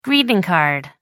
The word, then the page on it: greeting card: British English